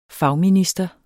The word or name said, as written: fagminister